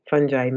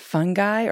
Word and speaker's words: fungi: And actually, I think this is a, a fun kind of um, little aside to ask like, how is it actually pronounced? Is it fungi or fungi? so um, you can say it either way. You can say fungi, fungi, fungi. In the US, people normally say fungi.